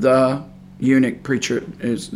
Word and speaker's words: eunuch: Some people get a little confused about eunuch because the U is silent on right after the E. So, capital E-U-N-U-C-H.